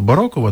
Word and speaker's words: барокова: Напевне, якщо Оксана Шевчук, наша режисерка, так каже, що правильно барокова, то, напевне, барокова. Бо режисери українського радіо і наголоси – це дуже серйозні люди. Тому повіримо її експертній думці і будемо далі казати барокова.